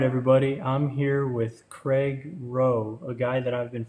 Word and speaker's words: Roh: So it's it's Craig Roh, right? I'm saying that right? – Yeah. Okay. Yeah, C R A I G R O H.